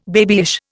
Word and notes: babyish: Indian

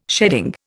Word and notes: sheading: Indian

